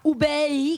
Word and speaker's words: Hubei: No, que no se vaya a Hubei, que está bien pronunciado, China.